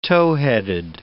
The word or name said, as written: towheaded